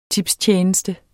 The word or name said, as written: tipstjeneste